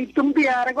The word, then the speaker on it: Itumbiara: Agora você falou certo, Itumbiara, Goiás. – Porque às vezes eu falo Itumbiara, né? – Era... Isso! Não é só o senhor, não. Muita gente. – Então tá bom. Itumbiara, Goiás.